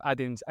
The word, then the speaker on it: Adin: Um, Adin's A D I N.